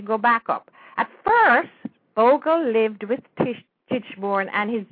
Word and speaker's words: Bogle: B-O-G-L-E. – B-U-G-L-E. Oh, Bugle. – O-G-L-E.